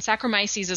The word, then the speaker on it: Saccharomyces: And Saccharomyces is one of those ones I'm like, I just said it twice correctly in the same episode.